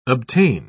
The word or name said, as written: obtain